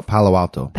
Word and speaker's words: Palo Alto: Is that right? Palo Alto? Alto? Did I say that right? Anyway. – Palo Alto. – Palo Alto. – Yeah, Palo Alto.